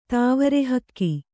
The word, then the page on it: ತಾವರೆಹಕ್ಕಿ: tāvare hakki